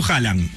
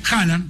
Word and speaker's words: Haaland: ¿Cómo lo pronuncias vos? – Yo digo Haaland. – Haaland, perfecto. Haaland.